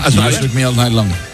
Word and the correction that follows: Shook Me All Night Long: Qué me cuesta el Shook Me All Night Long.